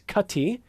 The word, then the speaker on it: Kati: Kono is how it's formally pronounced, and her name, K-A-T-I, is Cutty, but everybody mispronounces it.